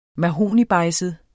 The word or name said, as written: mahognibejdset